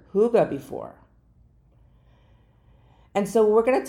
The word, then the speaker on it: hygge: Now, Huga is spelled H-Y-G-G-E. So you'd think it'd be like Hyg, Hyga. Well, it's Huga is how it's pronounced. Like H-U-G-A-H, Huga.